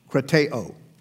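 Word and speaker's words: krateo (κρατέω): Krateo is how you say it. Emphasis on the O, krateo.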